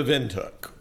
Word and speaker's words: Windhoek: It's called From Welcome to Windhoek. – To Windhoek.